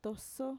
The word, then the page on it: toso: Mixtec